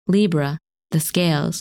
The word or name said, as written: Libra